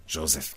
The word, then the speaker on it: Joseph: Bom, para a maior parte de nós, o nome Samuel Joseph Agnon, Samuel Joseph Agnon, se quisermos pôr mais na pronúncia inglesa,